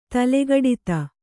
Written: ತಲೆಗಡಿತ

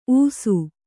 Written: ಊಸು

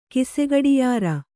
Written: ಕಿಸೆಗಡಿಯಾರ

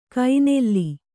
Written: ಕೈನೆಲ್ಲಿ

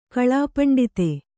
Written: ಕಲಾಪಂಡಿತ

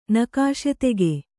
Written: ನಕಾಶತೆಗೆ

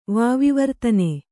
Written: ವಾವಿವರ್ತನೆ

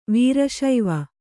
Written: ವೀರಶೈವ

